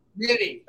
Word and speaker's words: Mitte: And of course, you know, the star of the film is, one of my favorites from Breaking Bad, RJ, Mitt, am I saying his last name right? – RJ Mitty. Okay. I've been mispronouncing it for all these years.